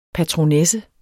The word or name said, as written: patronesse